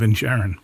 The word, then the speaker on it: Inisherin: And then you've got The Banshees of Inisherin. – Yes. – And that's very interesting because... – Because you pronounced it correctly. – Yes. – Most people can't.